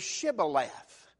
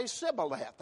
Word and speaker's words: Shibboleth: They had to pronounce a particular word. And for some reason, I mean, I don't know what it was, but the word was Shibboleth. And those people could not pronounce that word. Amen? They couldn't pronounce it. They'd say Shibboleth. They had a difficulty in forming part of that word.